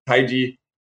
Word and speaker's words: Taiji: So like Taiji, you know, you see me pronounce it with the J instead of the CH, because the CH was the old pronunciation.